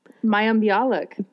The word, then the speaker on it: Mayim Bialik: That was Mayim Bialik, or however you say her name. – Mayim Bialik.